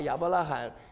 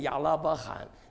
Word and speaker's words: 亚伯拉罕: 你只要不把亚伯拉罕念成亚拉伯罕，你就是个好弟兄。